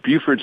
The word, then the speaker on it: Beaufort: Well, it's Beaufort, North Carolina, and it's Beaufort, South Carolina.